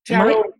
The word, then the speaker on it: tarot: I always said it tarot. – Tarot or tarot is appropriate.